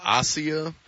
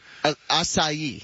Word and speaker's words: acai: I was calling it Asia. – Acai. – Oh, yeah, okay. – Yeah, so everybody funny. In L.A. knows how to pronounce acai. – Yeah, in Cleveland, not so much. – A-C-A-I.